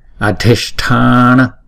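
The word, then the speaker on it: adhiṣṭhāna: Chữ S có dấu nặng thì chúng ta đọc cong lưỡi lại và búng ra. S. Chữ TH này nè. Có dấu nặng chúng ta đọc là T. T. Adhisthana.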